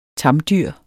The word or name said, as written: tamdyr